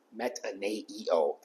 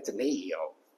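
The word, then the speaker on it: metanoeo: The word is metaneo. I'm sure I'm pronouncing that exactly correct. It's the word that leads to the idea of repentance. Metaneo. There's an extra E in there. Metaneo.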